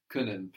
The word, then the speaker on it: Können: Können, good, with the O, with the umlaut. Können, pronounced like the vowel in bird.